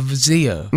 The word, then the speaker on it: Vizio: We see you have a Vizio TV. – Yeah. – And is that how you say it? – Yeah.